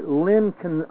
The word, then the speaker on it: Lencioni: His name's Patrick Lin. I never get his name right. Lencioni, Lencioni.